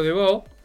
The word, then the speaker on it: Debaud: que es Pedro Debó, y se escribe Debaud, Pedro Debó.